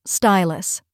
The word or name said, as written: stylus